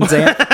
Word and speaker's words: Xanth: Damon Zant. The X is silent.